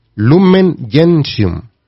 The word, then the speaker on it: Lumen Gentium: Lumen Gentium. Así se pronuncia, es latín.